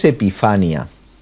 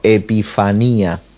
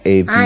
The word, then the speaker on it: Epifanía: Vamos a ver, no es Epifania, sino Epifanía. – ¿Con acento en la...? – En la I. – Epifanía. – No Epifania, sino Epifanía. – Ah, la segunda I, Epifanía. – Eso es, Epifanía.